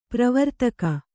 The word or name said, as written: ಪ್ರವರ್ತಕ